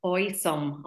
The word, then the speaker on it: Oysome: Sarah, lovely to meet you, and you're joining us this evening to talk about Oysome.